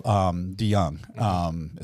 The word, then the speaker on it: DeYoung: Like Paul DeYoung. Is that right? Did I say that right? – Yes.